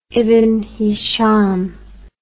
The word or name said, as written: ibn hisham